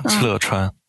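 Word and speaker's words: 敕勒川: 就赤勒川？ – 对，是的，是的，赤勒川。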